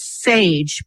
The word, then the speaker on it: Saje: The band is called Sage, pronounced like beige, but it's spelled S-A-J-E.